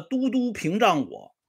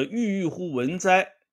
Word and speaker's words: 郁郁乎文哉: 在这个农村呢，这个民国时代，这老师呢教这些孩子们啊启蒙的时候，呃，读一个这个孔圣人说过的话，叫“ 郁郁乎文哉” 啊。我给大家打一下啊。郁郁乎文哉！就这五个字。但是这个先生呢，他学问有限，结果呢，他就读了白字了。他读成什么了呢？读成了“ 都督屏障我” 啊，“ 都督”。就把这个“ 郁郁乎文哉” 给读成了这么五个字。